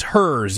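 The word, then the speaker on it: Herr's: It is Hers, you say, is the company.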